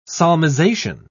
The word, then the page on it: solmization: British English